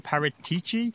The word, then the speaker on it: Paratici: Is it the sporting director, who I'm about to maybe pronounce incorrectly as Fabio Paratici? Is it Andrea Pirlo? Is it someone else? Like, who do you think has made this decision to get Weston McKennie to Juventus? – I'm fairly certain it's sporting director Fabio Paratici. You came very close. Good pronunciation.